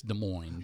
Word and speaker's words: Des Moines: If you're listening out in Des Moines right now, is that how you pronounce it? – I think it's Des Moines.